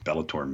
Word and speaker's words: Bellator: No one even knows what Bellator means. They just picked a word. – It rhymes with Skeletor.